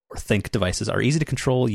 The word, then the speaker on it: ThinQ: It's you're right, where it's spelled out thin, a capital Q, but it's supposed to be pronounced think.